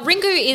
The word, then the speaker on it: Ringu: Ringu is, I believe, the Japanese title. Yeah, Ring. Yeah, and then Ring is the English.